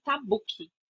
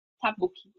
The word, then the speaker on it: Tabucchi: acredito que o sobrenome dele se pronuncia Tabucchi. Tabucchi.